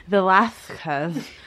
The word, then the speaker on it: Velázquez: Velasquez, or as the Habsburgs would have pronounced his name, Velazquez.